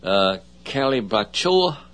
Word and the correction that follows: Calibrachoa: Well, that brought a round of applause. – Let's see. – Calibrachoa.